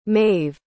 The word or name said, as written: maive